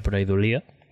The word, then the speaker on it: Pareidolia: En castellà és pareidòlia, crec que... Però ho vam demanar a un erudit lingüista amic nostre que mos va dir que ho havíem de pronunciar pareidòlia, ¿vale?